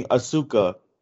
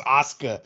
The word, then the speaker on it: Asuka: And I would always remember reading Asuka, but it's pronounced Asuka.